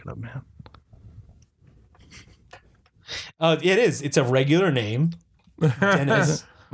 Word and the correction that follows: Denis: No, it is Denis. I think it's pronounced Denis.